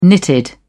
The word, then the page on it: knitted: British English